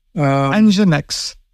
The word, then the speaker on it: Nginx: Is it NIX? – Nginx.